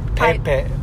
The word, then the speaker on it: Peipei: Before the wedding, we went to this place called Pie Pie. That's right. – And it's spelled P-E-I-P-E-I.